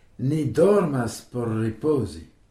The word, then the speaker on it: ripozi: Riposi, c'est se reposer. Riposi, ça s'écrit R-I-P-O-Z-I. Rappelons que pour le son Z, c'est la lettre Z que l'on prononce zo en espéranto.